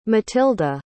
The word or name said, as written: mathilde